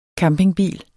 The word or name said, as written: campingbil